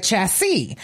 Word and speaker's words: chassis: And for trucks and automobiles, it contains the chassis. Am I saying that right? – The chassis. – Right.